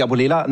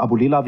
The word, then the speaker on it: Abulela: Tarek Abulela, Nachname hervorragend ausgesprochen. Ich war ja schon alles Abdullah. Ich sage immer Abulela wie Müller hilft aber auch nicht in der Aussprache.